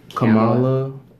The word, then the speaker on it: Kamala: Am I saying her name right? Kamala Harris? Kamala Harris? I think it's Kamala. – Something like that.